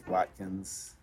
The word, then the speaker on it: Watkins: Daniel Joseph Watkins. – And just Watkins. – Yeah, W-A-T-K-I-N-S.